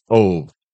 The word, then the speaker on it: Ove: I've been saying that wrong so much. – Which is a Swedish movie. Well, it's funny because when you hear it in the movie, right, it's really like his name is Ove, right? – And then there's like this little tiny noise.